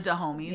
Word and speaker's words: Dahomey: and them being based off of the Dahomey, am I saying it right? Dahomey women. – The Dahomeys. Yeah.